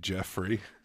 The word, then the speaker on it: Geoffrey: I think it's pronounced Jeffrey. – Yeah. – Geoffrey. – Geoffrey. – Yeah, I don't know why they spell it that way. – Though. Yeah. – G-E-O-R, yeah.